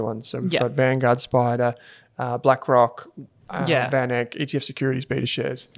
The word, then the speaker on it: SPDR: SPDR. Spider. Yeah. That's how it's said for short.